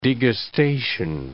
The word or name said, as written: degustation